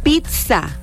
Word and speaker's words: pizza: Como dato adicional, para que sepan, las palabras que llevan doble Z, que vienen de origen italiano, uh-huh. Se pronuncia como TZ o como TS. – Mira. – Pizza.